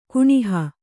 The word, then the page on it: ಕಾಣುಹ: kāṇuha